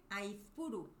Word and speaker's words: Aizpuru: Aizpuru.